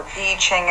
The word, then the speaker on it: I Ching: All right, we are going to wrap up what has grown into a three-part video discussing Taoism and I Ching, or I Ching as it's correctly pronounced.